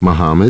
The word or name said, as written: Muhammad